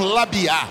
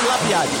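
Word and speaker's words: Labyad: Tem uns caras que falam Labiá, a pronúncia é Labiade ou Labiá, hein? – Marroquinamente falando, Marroquinamente. – Labiá. Ah, meu Deus. – Portuguesamente, Labiade. – Então tá bom. Vamos no português, né? Labiade.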